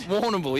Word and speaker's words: Warrnambool: Warrnambool. That's how you pronounce it, isn't it? – Warrnambool, yeah.